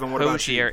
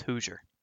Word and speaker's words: Hoosier: And it's not Hozier. It's not Hozier, it's Hoosier. Like, who's your daddy? – Hozier.